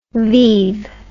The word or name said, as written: vive